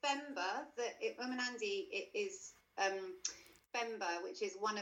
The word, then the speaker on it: Umunandi: Yeah, it's called Umunandi. – Umunandi, right.